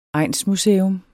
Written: egnsmuseum

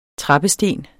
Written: trappesten

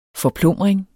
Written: forplumring

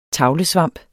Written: tavlesvamp

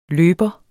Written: løber

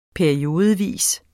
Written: periodevis

